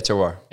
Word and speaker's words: terroir: But the two things that I kind of wanted to talk about was the, and you can correct my pronunciation on this, but terroir and is that correct? – Yeah, terroir.